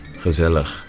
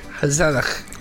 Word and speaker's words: gezellig: Gezellig. Yeah, remember that one. – There. I'm getting there with the pronunciation. – Gezellig. – Gezellig.